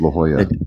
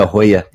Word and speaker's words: La Jolla: It looks like a J, yeah. But it's pronounced like it's La Jolla. That's correct. – La Jolla, yeah.